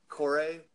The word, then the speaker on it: Cuore: It's Core, and that's a Swiss company. It's spelled C-U-O-R-E, and yep.